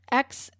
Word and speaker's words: Xochimilco: Xochimilco, but it's spelled with Xochimilco.